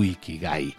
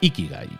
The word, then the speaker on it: ikigai: Y la regla número 10 es que sigas tu ikigai. No sé si lo digo bien, creo que es ikigai, ¿no? Creo que es con acento en la primera i, digamos, con inflexión en la primera i.